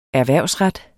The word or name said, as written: erhvervsret